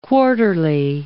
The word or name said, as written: quarterly